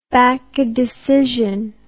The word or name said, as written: bakke decision